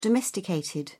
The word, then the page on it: domesticated: British English